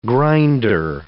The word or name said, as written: grinder